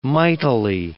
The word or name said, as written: mightily